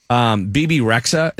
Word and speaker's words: Bebe Rexha: The first is Bebe Rexha.